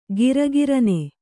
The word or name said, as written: ಘರಿಘರಿನೆ